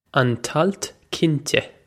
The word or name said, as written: an t-alt cinnte